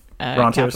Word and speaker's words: rentier: Rentiers. I'm saying it wrong the entire life. You let me say it wrong? – I think there's actually different ways of pronouncing it. I've always said rentier.